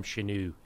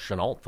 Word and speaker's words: Shenault: I like to call him Chenu. – Yep. – I think it's pronounced Shenault, though.